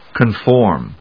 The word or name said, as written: conform